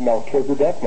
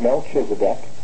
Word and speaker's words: Melchizedek: Anytime you see a proper noun capitalized, the CH, no matter where it appears in the word, is pronounced as a K. There's only one exception in the Bible, and that's Rachel. There, the CH is soft. It's not Rachel. Okay. But it's Melchizedek, not Melchizedek. It's not the Chebar River, it's the Kibar River. So it doesn't matter where you find that, a CH, it is pronounced as a K.